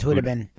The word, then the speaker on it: Khudobin: The goalie is a guy, it's spelled Khudobin, and I think it's Hudobin or something like that. – Yeah, I think it's Hudobin. – Yeah, because there was Hobby Bullen many years ago, and he had a silent K, so yep. I'm assuming that's the same thing with this guy, Anton Hudobin, who is a Russian goaltender now with the Dallas Stars.